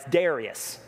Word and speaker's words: Darius: It's Darius, all right? It's Darius, in case you're wondering.